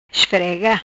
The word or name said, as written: Esfrega